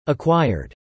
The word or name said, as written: acquired